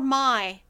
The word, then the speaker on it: My: She told me to call her My. It's spelled M-Y, just like saying my, like, something.